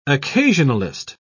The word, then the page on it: occasionalist: British English